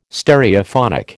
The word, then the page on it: Stereophonic: American English